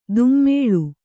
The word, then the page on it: ದುಮ್ಮೇಳು: dummēḷu